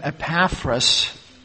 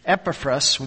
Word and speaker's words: Epaphras: I've pronounced his name Epaphras this morning. Epaphras would probably be more accurate. That epat, that ah sound, that is so American. That is when we like to put that in.